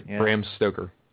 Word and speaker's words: Bram Stoker: Brimstroker is Dracula. – Yes. – Or Bram Stoker, how you say it. – Bram Stoker.